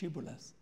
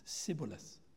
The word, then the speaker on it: Shibboleth: Shibboleth. They couldn't pronounce it, they said Sibboleth for the defect of their tongues.